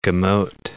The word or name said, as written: gemot